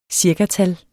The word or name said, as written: cirkatal